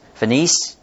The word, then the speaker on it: Phoenice: We see the Word of God went to, and churches were started in, I keep pronouncing this word wrong, Phoenix, the area of Phoenix, Phoenice, Cyprus, Antioch, and others.